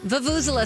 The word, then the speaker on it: Vuvuzela: Vuvuzela, Steve.